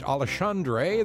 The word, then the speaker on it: Alexandre: His first name looks like Alexander, except it ends with R-E. It's pronounced Alexandre.